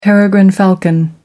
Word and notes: peregrine falcon: American English

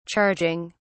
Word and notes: charging: British English

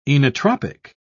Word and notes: inotropic: British English

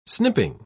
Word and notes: snipping: English; British English

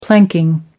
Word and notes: planking: American English

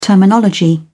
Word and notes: terminology: British English

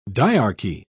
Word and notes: dyarchy: British English

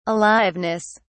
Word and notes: aliveness: British English

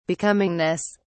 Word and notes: becomingness: British English

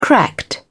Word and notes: cracked: British English

